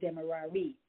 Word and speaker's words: Demerary: or back then it was called Demerary. It was spelled D-E-M-E-R-A-R-Y, and now it is spelled D-E-M-E-R-A-R-A, so Demerara, but it's the same place.